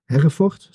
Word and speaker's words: Hereford: Hereford.